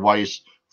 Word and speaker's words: Weiss: People will see it as W-E-I-S-S. In German, the W is pronounced as a V, so it's Weiss.